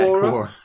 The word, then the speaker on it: Quora: Is it Quora? – Yeah, Quora.